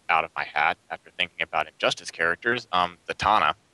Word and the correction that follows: Zatanna: Oh, Zatanna. – Oh, yeah. – Zatanna, sorry.